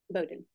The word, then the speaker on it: Bowden: Bowden.